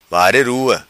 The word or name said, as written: vare rua